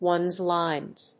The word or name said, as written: ones lines